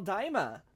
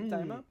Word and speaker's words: Daima: So also we got the first trailer for Dragon Ball Daima. Am I saying that right? Daima. – I would assume so. – Releasing. – I'm pronouncing as Daima. So yeah. – Okay, perfect.